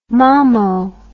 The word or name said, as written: mamo